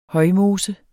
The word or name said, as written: højmose